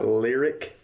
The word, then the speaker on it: Lyriq: Dodge, Ram, Volkswagen, and then they've got another one called, am I pronouncing this right, Lyriq? – Yeah. – L-Y-R-I-Q, looks like. – Yeah, it's Lyriq.